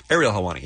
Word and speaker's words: Ariel Helwani: Also on the program today, we have, we're going to talk to, I'm not sure how to say this. I think it's Ariel Helwani? – I think it's Ariel. – Ariel. – Ariel Helwani, yeah.